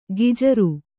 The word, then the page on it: ಗಿಜರು: gijaru